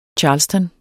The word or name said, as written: charleston